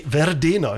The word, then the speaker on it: Verdena: Die zweite Band ist die Band Verdena. Jetzt hätte ich fast auf Englisch ausgesprochen. Ich kann gar kein Italienisch, aber ich habe es jetzt ganz Italienisch ausgesprochen. Und Verdena, das kennt komischerweise in Italien auch wirklich so in dem Indie-Bereich jeder anscheinend.